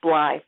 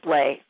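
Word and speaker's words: Bley: that I just assumed that you pronounced Carla's name as Bly, but it's Blay.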